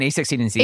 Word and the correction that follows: a16z: A lot of people do say A16, but it's A16Z. – Yeah. – So, but you were doing it A16Z and Z. You added an and Z.